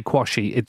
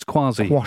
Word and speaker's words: Quashie: Please don't ever say Kwasi. It's Kwasi. – Kwasi, it's spelt Q-U-A-S-H-I-E. – Yeah, but it's pronounced so Kwasi.